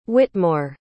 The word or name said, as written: whitmore